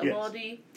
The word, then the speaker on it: Uvalde: Uvalde uh, massacre hap- uh, came out.